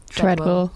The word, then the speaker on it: Treadwell: Treadwell, wie spricht man es aus? – Treadwell. Treadwell.